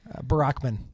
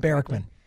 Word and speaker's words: Barrickman: You know, is it Barrickman? – Barrickman. – Oh, stop it. – Like Barack Obama. – Please help me pronounce his name. – Barrickman.